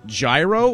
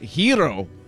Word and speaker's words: gyro: Like, is it gyro or is it hero? Well, they told me it's the second one.